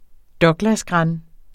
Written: douglasgran

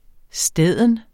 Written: steden

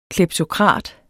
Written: kleptokrat

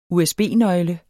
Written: usb-nøgle

